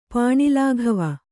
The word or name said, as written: ಪಾಣಿಲಾಘವ